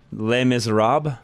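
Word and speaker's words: Les Misérables: Les Miserables? – Yeah, Les Miserables.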